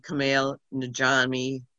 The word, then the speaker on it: Kumail Nanjiani: It stars Issa Rae and Kumail Nanjiani, and I just screwed up his name.